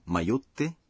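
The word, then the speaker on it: mayotte (迷って): Para pronunciar mayotte, faça uma pausa depois de mayo.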